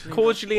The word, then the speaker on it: cordially: Cordially invited. Like you pour, like what squash used to be called when you were posh.